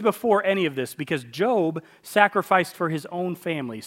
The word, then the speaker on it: Job: It's spelled J-O-B, but it's not Job, it's Job.